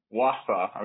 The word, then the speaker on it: Wausau: Wausau.